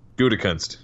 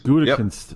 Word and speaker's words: Gutekunst: Gutekunst. – Gutekunst.